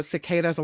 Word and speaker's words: cicadas: Oh, cicadas a long A. Thank you. Yeah, I always say it wrong.